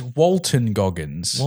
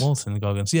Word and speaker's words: Walton Goggins: So it's Walton Goggins. – Walton Goggins.